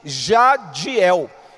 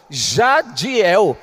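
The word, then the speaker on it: Jadhiel: E para complicar, tem um H entre o D e o I. J-A-D-H-I-E-L. Então você imagina os estrangeiros querendo pronunciar o meu nome. Jadiel, Jariel, até rádio me chamava. O senhor rádio. Eu não sou rádio.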